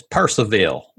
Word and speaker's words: Purcellville: per- it, yeah, it's it's spelled Purcellville, but that those L's in the middle, people just it's Percival.